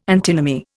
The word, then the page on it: antinomy: Indian